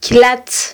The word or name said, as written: kylatz